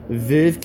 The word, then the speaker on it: Veuve: Is that how you say it? – Just Veuve. – Veuve Cliquot.